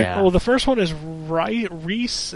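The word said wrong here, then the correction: Ryse: I think it's pronounced Rise, just spelled wrong.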